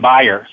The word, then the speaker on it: Bayer: Let me, uh, well, first of all, Bayer, we pronounce it in, our, in the United States, we call it Bayer because of Bayer aspirin. Outside the United States, we actually pronounce our name Bayer.